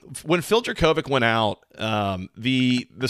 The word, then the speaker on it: Jurkovec: You know, so we mentioned that they've struggled without Phil Jurkovec uh, here. I'm almost positive I'm pronouncing that wrong. Um, Jurkovec. – No, you're doing it right. You're oh, doing yeah. it right, Jurkovec.